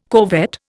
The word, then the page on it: corvette: Indian